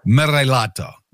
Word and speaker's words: Morellato: Morellato.